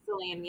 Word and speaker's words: Selene: We finally get to see the true form of our baddie, Selene. Is that correct? Am I saying that right? – Selene, yeah.